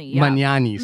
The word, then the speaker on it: Magnani: Magnani, sorry, mm-hmm.